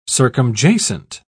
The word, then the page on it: circumjacent: British English